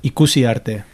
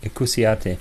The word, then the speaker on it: Ikusi Arte: Now can you say it properly? – Ikusi Arte. You just say it right. – Ikusi Arte. Yeah, perfect. That's pretty good.